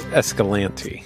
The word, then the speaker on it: Escalante: They did vote on it, actually, and it is Escalante. – That's what the town said, but most people say Escalante. – You and I say Escalante. The locals say Escalante.